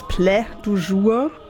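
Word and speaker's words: Plat du jour: Das war jetzt musikalisch auch nicht mein Favorit heute, aber ich dachte an sein Konzeptalbum, es schreibt sich Platt du jour. Anscheinend spricht man es Plä du jour.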